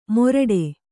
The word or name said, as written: ಮೊರಟೆ